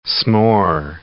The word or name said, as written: s'more